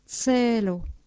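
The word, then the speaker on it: celo: C se prononce comme un T suivi d'un S, cello, un but.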